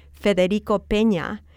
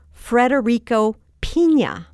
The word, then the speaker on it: Federico Peña: And one day I remember yelling at my radio because they were talking about the former Secretary of Transportation, Federico Peña, but they pronounced his name Frederico Piña. Mm. Well, a piña is a pineapple. And there's only one R in Federico. It's not Frederico.